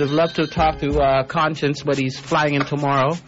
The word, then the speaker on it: Konshens: I'm going to play some Conscience next. You know, an artist that's also on the show. And that's spelled K-O-N-S-H-E-N-S, in case you never know.